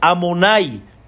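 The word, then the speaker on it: Adonai: Y este nombre no se pronuncia como está escrito. Aquí nosotros no pronunciamos ni la Yud ni la Hei, pronunciamos nosotros Amonai.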